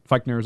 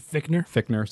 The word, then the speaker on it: Fichtner: And then under him is Bill Fichtner, is that right? I said that wrong. – Fichtner, said it wrong twice now.